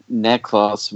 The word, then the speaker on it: Neclaus: It is Neklos, which means Nestle's little one. – Oh. – But they spell it N-E-C-L-A-U-S.